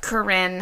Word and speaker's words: Korine: Corinne.